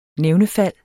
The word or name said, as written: nævnefald